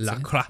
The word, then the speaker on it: LaCroix: And I think you would actually pronounce it LaCroix.